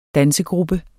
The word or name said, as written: dansegruppe